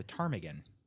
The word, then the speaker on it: ptarmigan: It's a ptarmigan. – Ptarmigan. So the P is silent.